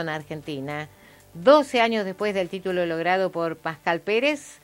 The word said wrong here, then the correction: Pascual Pérez: Pascual Pérez, lo dije mal, se impone ante el japonés Kazuyoshi Takayama, en Tokio.